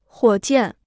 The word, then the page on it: 火箭: huǒ jiàn